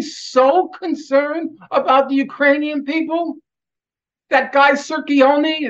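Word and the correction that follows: Cirincione: Joe Sirincione.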